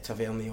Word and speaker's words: Tavernier: I'd probably take Tavernier over Logan on that. – I like that you're going for the French pronunciation.